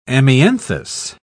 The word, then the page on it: amianthus: British English